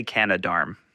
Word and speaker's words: Canadarm: What's the official pronunciation of the Canada arm? – I say Canadarm.